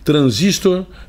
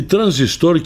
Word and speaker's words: transistor: A palavra transistor ou transistor pode ser pronunciada de qualquer uma das duas formas. Transistor é a moda inglesa e transistor, que eu prefiro, é a forma brasileira ou portuguesa para rimar com capacitor, conector, resistor.